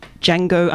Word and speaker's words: Django: D-J-A-N-G-O. D is silent.